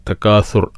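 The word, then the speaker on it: تكاثر: അൽഹാക്കുമു തക്കാസുർ, തക്കാസുർ അഥ അലിഫ് ബ ത ഥ അക്ഷരാണ്. അപ്പം ശരിക്ക് ഉച്ചാരണം ശ്രദ്ധിക്കുക. തക്കാസുർ സ എന്ന് ഉച്ചരിക്കരുത്.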